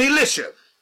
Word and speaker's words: Elisha: And I believe it was Elisha, because both names are closely pronounced. Elijah, S-H-A, and Elijah, J-A-H. So to get a proper distinction between both, I always use Elijah and Elisha.